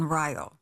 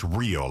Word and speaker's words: Rio: And my mother had grown up in Ryo. My mother was born in 1903. – And it's not pronounced Rio, like Rio de Janeiro. – Yeah, you would think so. – It's Ryo.